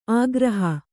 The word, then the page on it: ಆಗ್ರಹ: āgraha